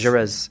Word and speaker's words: Jaurès: And of course, there were those great leaders like Jaurès here. Is that how you pronounce it? – Jaurès.